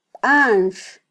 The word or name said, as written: banx